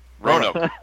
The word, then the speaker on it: Roanoke: And that's how I know you're not a local, and neither am I, because we're still using three syllables to describe the name of the city. – Roanoke. Oh. – Two. Can be one, actually. That's right. Noke. The Noke.